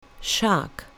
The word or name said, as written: shark